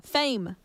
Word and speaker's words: Faim: The one after that was Fame. Like F-A-I-M, but pronounced fame.